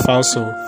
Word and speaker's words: falso: It's actually pronounced falso.